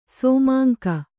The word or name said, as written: ಸೋಮಾಂಕ